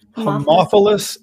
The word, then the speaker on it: Haemophilus: Haemophilus influenza type B.